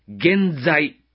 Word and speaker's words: 原罪: 原罪というのは、原因の罪と書いて、原罪と読むんですが、人間が生まれながらに持っている罪の性質を指しています。